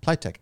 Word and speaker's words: Pliteq: It's spelled P-L-I-T-E-Q. – Pliable technology is where that comes from. – That's where it comes from? – Yeah.